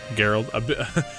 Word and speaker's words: Geralt: Geralt.